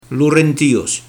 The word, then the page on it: Hlorentias: Lourentiyess